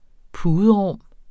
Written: puderum

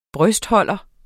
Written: brystholder

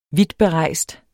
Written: vidtberejst

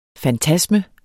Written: fantasme

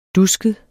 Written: dusket

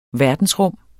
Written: verdensrum

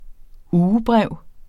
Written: ugebrev

